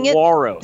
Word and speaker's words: saguaro: They're called sequoia cacti. – Close. – What am I mispronouncing Saguaro. – It? Saguaro. – Saguaro. – Yeah.